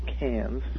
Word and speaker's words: Cairns: Which it's spelled Cairns, but they right. – pronounce it Cairns.